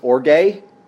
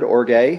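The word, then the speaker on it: orge (ὀργή): Anger, that word that you see there is pronounced orge. That's a four-letter Greek word, orge.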